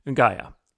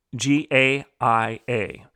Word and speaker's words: Gaia: this goddess that was called and is called today Gaia, G A I A. Some pronounce it Gaia.